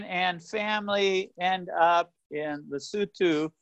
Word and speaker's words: Lesotho: So a lot of people see the name Lesotho and they think Lesotho because that's how it's spelled. So it's the place is called Lesotho, the people are called Basutu, and the language is called Sesotho.